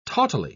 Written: tautly

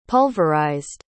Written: pulverized

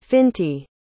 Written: Finty

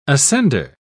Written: ascender

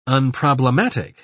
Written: unproblematic

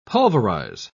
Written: pulverize

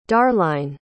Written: darline

